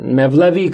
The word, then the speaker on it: Mevlevi: Mevlevi comes, it's a Turkish pronunciation of the word Molavi, uh, which comes from Molana.